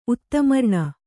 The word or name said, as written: ಉತ್ತಮರ್ಣ